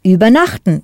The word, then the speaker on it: übernachten: Übernachten, which means to overnight. Note there's no stress on the first syllable, so it's not separable.